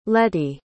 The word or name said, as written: letti